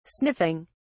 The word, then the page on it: sniffing: British English